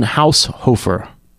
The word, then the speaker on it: Haushofer: It's Martin Haushofer. – Okay, I'm going to, let me read it. Marlen, M-A-R-L-E-N. H-A-U-S-H-O-F-E-R. – Haushofer. – Is that how you pronounce it? – It is.